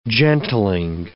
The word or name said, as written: gentling